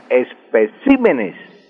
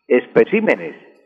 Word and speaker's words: especímenes: Y su plural sí se pronuncia corriendo el acento de una sílaba a la derecha. Si tengo, por ejemplo, espécimen, que es la palabra correcta, su plural sería, corriendo hacia la derecha una sílaba, sería especímenes, especímenes, lo mismo que régimen.